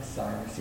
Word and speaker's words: Cyrus: We're running out of time, but I love the look up King Cyrus. Am I saying that right? Cyrus, yeah.